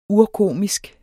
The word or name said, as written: urkomisk